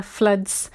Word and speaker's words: floods: Floods. Notice the pronunciation, not flood. Floods. F-L-O-O-D.